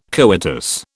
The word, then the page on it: coitus: British English